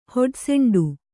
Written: ಹೊಡೆಸೆಂಡು

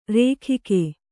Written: ರೊಖ್ಖ

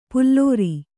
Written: ಪುಲ್ಲೋರಿ

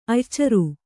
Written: ಅಯ್ಯಾಚಾರ

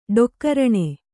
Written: ಡೊಕ್ಕರಣೆ

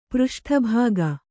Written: ಪೃಷ್ಠಭಾಗ

